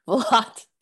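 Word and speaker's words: Valotte: When I was growing up, I'm from Detroit, but I lived in Florida, and I totally went to your, was it, how do you pronounce it? Valat? – Valat, yeah.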